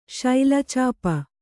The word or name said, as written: ಶೈಲಚಾಪ